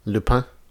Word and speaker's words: Lupin: Lupin. L-U-P-I-N.